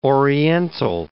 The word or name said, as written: oriental